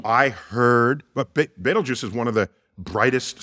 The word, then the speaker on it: Betelgeuse: Betelgeuse, it is pronounced Betelgeuse, like the film, but spelled differently.